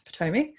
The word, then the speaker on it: Potomac: Well, you gave me a very stern talking to a week or so ago to let me know that I was saying it wrong, and now I've corrected. It's Potomac.